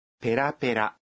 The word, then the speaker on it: بيرا بيرا: نعم، ولكن النبرة تختلف إذ تنطق هكذا بيرا بيرا.